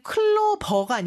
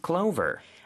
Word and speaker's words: clover: 그리고 클로버가 아니고 clover 이렇게 V 발음 주의해서 해주세요. 우리 연습해볼까요? – I found a four-leaf clover.